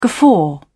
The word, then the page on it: guffaw: British English